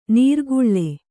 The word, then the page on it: ನೀರ್ಗುಳ್ಳೆ: nīrguḷḷe